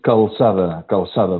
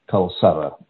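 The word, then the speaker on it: Calçada: Yeah, my last name is Calzada. The second C is a soft C, is a C with a slice, like in French, François, is like an S, Calzada, Mikkel Calzada.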